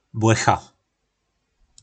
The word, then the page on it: Blecha: po nasimu